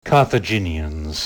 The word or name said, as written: Carthaginians